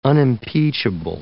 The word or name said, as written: unimpeachable